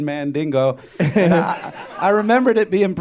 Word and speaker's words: Mandingo: It's pronounced Mandingo, first of all.